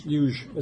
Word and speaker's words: yuj: The word yoga comes from the Sanskrit word yuj, which is spelled Y-U-J, and there's nothing to do with Bernie Sanders. Yes, yuj. It's actually, the J is soft. It's pronounced yuj.